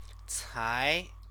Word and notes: cai: chinese

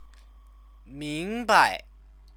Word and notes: mingbai: chinese

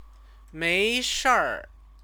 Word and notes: meishir: chinese

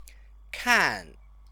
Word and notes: kan: Chinese

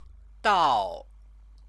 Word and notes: dao: chinese

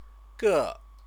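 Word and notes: 个: gè; Chinese

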